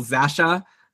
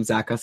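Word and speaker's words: Zacha: There's also Pavel Zasha, who I know that Brian always says I mispronounce his name, and then Peter Harlan called him Zaka, so now maybe I think that I'm correct. Maybe it's been Pavel Zaka this whole time.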